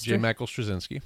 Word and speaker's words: Straczynski: We have, how do you say his name? You said him already. Straczynski? – J. Michael Straczynski.